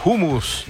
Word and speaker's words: hummus: Es hummus, esa es la pronunciación. – Así es, hummus. – La J, la H en árabe se lee como una J suave.